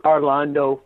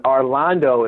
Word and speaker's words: Arlando: Arlando Fortune. So that's letter D as in Dwight. And Arlando is similar to, spelled like Orlando, but with an A. So D. Arlando, A-R-L-A-N-D-O, Fortune, F-O-R-T-U-N-E.